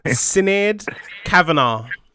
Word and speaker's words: Sinead Kavanagh: Sinead Kavanagh. – Sinead Kavanagh.